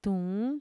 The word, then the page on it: tuun: Mixtec